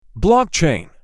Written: blockchain

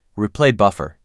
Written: Replay buffer

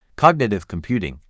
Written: cognitive computing

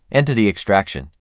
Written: Entity extraction

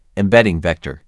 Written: embedding vector

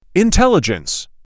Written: Intelligence

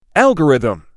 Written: algorithm

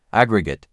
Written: aggregate

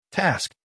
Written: Task

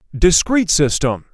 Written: discrete system

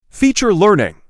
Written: Feature learning

